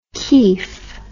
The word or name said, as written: keif